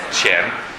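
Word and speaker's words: qian: Cien. Soldi. – E come si scrive? – Q-I-A-N. Q-I-A-N.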